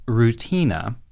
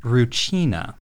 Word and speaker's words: Rutina: The name of the girl, it's written Rutina, but it's pronounced Rucina. To my ear, Rutina both sounds like I'm just saying the word routine and also like I'm describing some sort of root vegetable. So I prefer Rucina.